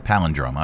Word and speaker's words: palindrome: How do you pronounce that? Palindrome? Is that how you pronounce that? Palindrome? – Palindrome. – I got it right.